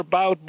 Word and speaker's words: Bout: As far as Boot or Bout, the Victor Bout, Boot, whatever way they pronounce it differently, Russian is Boot and American, or, the English way.